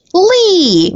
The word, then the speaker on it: Leigh: Is it Lay or Lee? Lee. Lee.